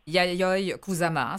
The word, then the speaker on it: Yayoi Kusama: D'abord, bon, juste vérifier, j'ai bien prononcé son nom, Yayoi Kusama, hein, c'est ça? – Oui, c'est très bien.